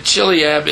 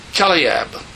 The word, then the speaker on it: Chileab: By the way, pronouncing it Chileab is not right. It's Chileab. It's pronounced with a K. At least the Hebrew understand.